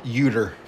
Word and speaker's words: Yuta: Fourth match, Wheeler Yuta defeated Dante Martin, Rey Fenix, and Roosh in a four-way. – you pronounced that wrong. Roosh. It's Uter. Uter.